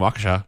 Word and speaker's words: Waukesha: A gong for new Leon Bridges and a gong for We heard from Nicole from Waukesha, Wisconsin. Is that how you pronounce it? – Nice. Yeah. – Is it? – Yeah, from Waukesha.